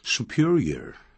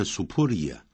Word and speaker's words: superior: 常用这样的这类的形容词啊，一共有四个，像 superior to 比什么更好更优越， inferior to 比什么差劲 inferior， superior 这样念啊，不要念 superior inferior，不是这样念， superior superior inferior, inferior, senior, 不要念成 senior, senior, 比什么年长的都要加 to, 或者比什么地位高的叫 senior, junior, 比什么年纪轻的或比什么地位低的我们都要用 to 这样用法。